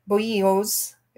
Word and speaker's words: bolillos: So she took us to her favorite vendor that sells bolillos. B O L L I L O is how it's spelled, uh, but it's pronounced, their double L's are pronounced like Y's.